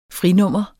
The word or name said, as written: frinummer